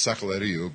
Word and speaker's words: Sakaleriu: I know what that feels like when people pronounce my last name like some avian flu virus. Mr. SARS. No, it's SARS. Well, actually, it's Sakaleriu, but my grandfather thought it was too long for the restaurant window, so it became SARS.